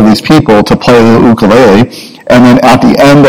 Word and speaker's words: ukulele: Now, you have to say it correctly, because if you've been in Hawaii, you can't say a ukulele, because that's the American way of saying it. But in Hawaii, it's an ukulele. That's how you pronounce it.